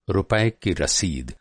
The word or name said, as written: रसीद